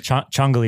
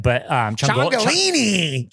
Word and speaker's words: Ciongoli: I mean, there's a bunch of different. Ciongoli is the real right way to say it. – Ciongoli? – Ciongoli is the real right way to say it. But Ciongolini. – Ciongoli is fine. Yeah. So that's my wife says Ciongoli. My brother says Ciongoli.